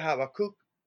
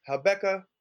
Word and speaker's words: Habakkuk: All right you know, we're at uh, I you know I always pronounce it Habakkuk, but Habakkuk, I'm cool with that.